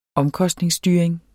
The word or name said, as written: omkostningsstyring